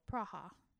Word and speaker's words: Praha: Praha. – Praha-ha-ha. – Praha, as the locals say.